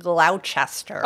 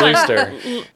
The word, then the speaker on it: Gloucester: I had to check the pronunciation because I knew that there's no way that people in Massachusetts were saying Gloucester. – Oh, I know that's... – Gloucester. – Gloucester? – Gloucester? Gloucester. – Google said Gloucester.